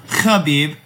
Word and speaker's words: Khabib: Pronounced Khabib, smoked him.